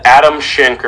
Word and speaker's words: Adam Schenk: But Adam Schenk, or whatever his name is. – That's pronounced correctly.